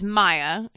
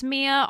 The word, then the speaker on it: Miya: I said her name wrong last time. I thought it was Maya. She spells it M-I-Y-A. – That seems like Maya. – Well, now that I know it's Miya, I can kind of see how it's Miya, although I still think Maya.